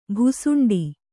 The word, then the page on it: ಭುಸುಂಡಿ: Kannada